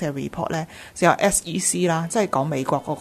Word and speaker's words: SEC: 我哋逢亲见到 SEC 三个字 capital 嘅，咁你就要即指翻佢系要读三个 letters，就唔好读嗰做 set。